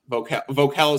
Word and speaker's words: Voelkel: V-O-E-L-K-E-L. Vocal. Vocal is how you pronounce it. – V-O-E-L-K.